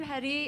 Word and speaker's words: Hetty: I'm Hetty. It rhymes with spaghetti.